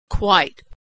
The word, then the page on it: quite: /w/